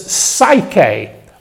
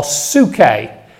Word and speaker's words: psyche: Then there's psyche or suke, depending how you want to pronounce it. We normally think of this spelt with a Y. P-S-Y-C-H-E is the English word.